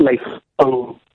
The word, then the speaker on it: Lei Feng: Lei Feng. Is that right? – You got it right. Lei Feng.